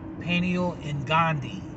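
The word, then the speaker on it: Peniel Ngonde: His name is spelled P-E-N-I-E-L, and his last name is N, like Nancy, G-O-N-D-E, Penny Ongandi.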